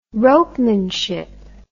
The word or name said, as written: ropemanship